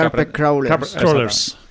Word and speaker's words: Carpet Crawlers: Bueno, la verdad es que de Carpet Crawlers, eh, ¿cómo se pronuncia? Yo es que nunca... – Carpet Crawlers. crawlers. exacto.